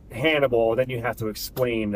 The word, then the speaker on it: HNBL: Pronounced as Hannibal. – Yeah, exactly. Yes.